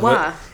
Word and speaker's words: Wawa: I guess people don't pronounce Wawa correctly, so it's like it rhymes. Wawa. It rhymes with saw.